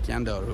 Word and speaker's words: Scandal: Scandal, but it's pronounced Scandaru.